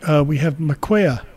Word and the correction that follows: Markia: Hope I pronounced that correctly. You should be able to unmute and join us. – Oh, sorry. No, it's Markia. – Oh, hey, Markia.